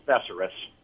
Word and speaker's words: thesaurus: The correct pronunciation is actually thesaurus. It's not a dinosaur. It's not a saurus. It's thesaurus.